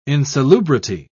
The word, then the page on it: insalubrity: British English